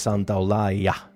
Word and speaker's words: Santaolalla: And I've played some Santaolalla. Always have to pronounce it the Argentine way, since that is where he is from. For you conventional Spanish speakers, that is Santaolalla.